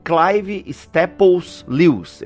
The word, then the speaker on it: Clive Staples Lewis: Clive Staples Lewis, é assim que se pronuncia? – Exato.